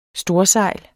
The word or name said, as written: STORSEJL